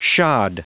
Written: shod

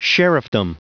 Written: sheriffdom

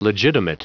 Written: legitimate

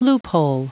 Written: loophole

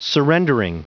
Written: surrendering

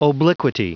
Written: obliquity

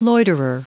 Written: loiterer

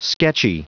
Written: sketchy

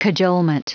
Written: cajolement

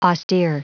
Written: austere

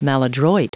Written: maladroit

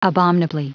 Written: abominably

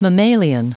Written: mammalian

